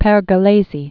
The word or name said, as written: Pergolesi, Giovanni Battista